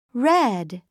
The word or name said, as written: red